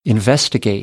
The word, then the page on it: investigate: American English; ə